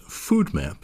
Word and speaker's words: FODMAP: She's going to tell us today about the pros and cons of an approach to diet spelled FODMAP, which I think should be pronounced FODMAP, because, of course, it is a map to what you're going to eat.